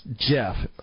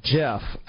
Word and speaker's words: GIF: It's supposed to be pronounced GIF, even though it's spelled G-I-F, graphic interchange format. It's pronounced GIF, as in the peanut butter, and not GIF, as in gifting someone something.